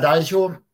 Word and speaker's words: Dalchow: It's Rüdiger Dalchow, but it's a little bit hard to spell.